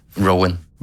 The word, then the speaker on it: Roin: Er det rigtigt udtalt? – Det er det ikke, men det gør ikke noget på nuværende tidspunkt. – Prøv lige at udtale det rigtigt. – Rowan.